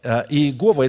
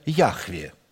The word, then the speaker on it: Яхве: И поэтому вот это Иегова – это неправильное произношение Яхве.